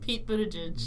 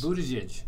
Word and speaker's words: Buttigieg: The final winner was Pete, however you pronounce his last name? – Pete Buttigieg. – Buttigieg.